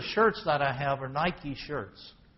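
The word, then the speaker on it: Nike (Νίκη): In Greek it's pronounced Nike.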